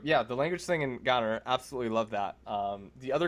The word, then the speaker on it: GoNNER: that's actually the way to say GoNNER, is like GoNNER. – Okay. – That's the proper way to say the game title.